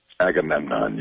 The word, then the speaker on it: Agamemnon: Keep in mind, Patrick is the voice of, am I pronouncing this right, Agamemnon? – Agamemnon, yes.